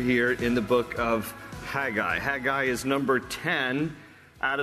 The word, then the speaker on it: Haggai: His name in Hebrew is spelled with a C-H, just as that huh sound, so it's Haggai. Haggai is his name.